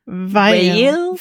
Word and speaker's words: Veil: Wie spricht man das aus? V-E-I-L, so wie Pfeilchen ohne C-H-E-N. Veil. Veil.